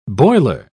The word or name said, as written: boiler